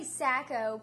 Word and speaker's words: Sacco: No, it's actually Sacco, but I've learned just to, you know, as long as people don't say psycho or, you know.